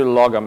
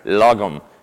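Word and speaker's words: lagom: You know, people have questioned us a little bit about this, but it's a Swedish word that means just the right amount. And I've been working really hard on my pronunciation here. Logum. Is that correct?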